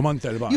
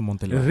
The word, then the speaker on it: Montalban: The Montalban. – You have to say it like that. – Ricardo Montalban. – Ricardo Montalban.